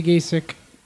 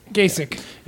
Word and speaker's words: Gacek: My last name is G-A-C-E-K Gasek. – That was just a bunch of weird syllables. – Yeah, it's just Polish. – So Peter W G Gasek. – Gasek.